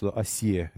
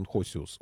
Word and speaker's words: Хосиус: А, у нас Осия иногда называют. Ну, правильно, все-таки он Хосиус, Осий. Так произносить его имя а, потихонечку узнал и о содержимом Евангелия, и о жизни Иисуса Христа.